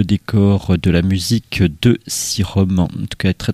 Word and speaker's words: Sirom: Les Sirom, comme ça se prononce, S-I-R-O-M, qu'on avait découvert avec un autre incroyable disque qui s'appelait The Liquefied Throne of Simplicity.